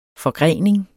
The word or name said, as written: forgrening